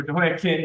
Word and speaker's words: Jehoiachin: Jehoiachin, or Jehoiakim, it's really the CH is pronounced like that. So Jehoiakim.